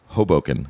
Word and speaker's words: Hoboken: I think it's pronounced Hoboken.